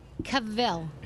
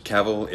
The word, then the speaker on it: Cavill: Henry Cavill. – Here it is. – Cavill is pronounced like travel.